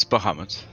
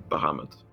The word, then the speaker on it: Bahamut: Speaking of him, have you ever spoken with Bahamut? Or Bahamut? Sorry, I don't know if I'm pronouncing that right. – It's Bahamut. That's fine. – Bahamut.